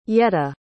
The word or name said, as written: yetta